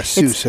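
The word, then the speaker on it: Seuss: Not Zeus. Right. I said that once before. – Yeah, Seus, as in Seus, Dr. Seus.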